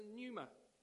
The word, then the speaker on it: pneuma: The second word is pneuma. There's a silent P on the beginning of that, but it's pneuma.